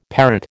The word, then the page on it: parrot: American English